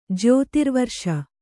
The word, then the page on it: ಜ್ಯೋತಿರ್ವರ್ಷ: jyōtirvarṣa